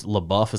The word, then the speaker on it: LaBeouf: It's LaBeouf, is that how you say his name? – Mm-hmm.